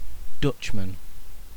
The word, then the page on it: dutchman: British English